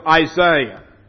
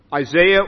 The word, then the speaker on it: Isaiah: Go with me to the book of Isaiah. Isaiah or Isaiah, as you pronounce it here.